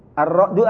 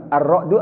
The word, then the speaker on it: Ar-Ra'd: Kemudian kita Indonesiakan surat Ar-Ra'du atau Ar-Ra'd. Gimana, Pak? Ar-Ra'du atau Ar-Ra'd? Ya, dua-duanya benar.